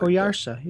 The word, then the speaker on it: Oyarsa: Oyarsa, you got it.